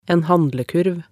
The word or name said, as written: en handlekurv